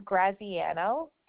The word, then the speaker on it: Graziano: Last name is Graziano. – Can you spell that for me? – Sure, sorry. It's G-R-A, Z as in zebra, I-A-N-O.